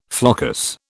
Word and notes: floccus: British English

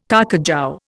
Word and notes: carcajou: Indian

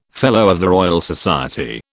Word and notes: FRS: British English

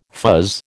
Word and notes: fuzz: American English

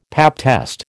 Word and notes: pap test: American English

